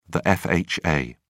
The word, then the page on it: fha: British English